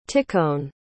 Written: tikhon

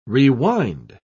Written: rewind

